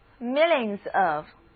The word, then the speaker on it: millions: 字母组合 IO 的发音是 ear，词组 millions of， millions of， millions 显然是百万的复数形式，因为后面有一个 s，所以好几个百万的什么什么东西，那可真是数也数不过来了。